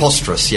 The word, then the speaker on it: Posterous: Posterous, yeah.